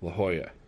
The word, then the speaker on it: La Jolla: So I write Lahoya. La Jolla. – La Jolla. – Actually, La Jolla is spelled La Jolla. – La Jolla. Yeah. J-O-L-L.